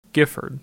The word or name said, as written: Gifford